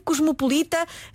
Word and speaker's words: cosmopolita: O adjetivo cosmopolita não tem qualquer acento gráfico, pronuncia-se com as vogais fechadas, cosmopolita, e é a única forma que está atestada em português.